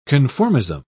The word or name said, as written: conformism